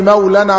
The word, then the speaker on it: مولى: يوم اصل الجمله كده، يوم لا يغني مولى عن مولى شيئا، ده النطق الاعرابي. يوم لا يغني مولى عن مولى شيئا، النطق بتاعنا تجويدي. يوم لا يغني مولا عن مولا شيئا، فانا وصلت اهو الكلام.